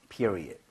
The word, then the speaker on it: period: period는 앞에 이에다 강세를 좀 많이 주시면 좋아요.